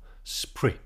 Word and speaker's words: spirit: And spirit here is pronounced as one syllable, sprit.